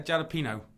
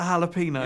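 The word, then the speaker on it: jalapeño: Your cat ate a jalapeno. – Yeah, you're sneezing. – The J is silent.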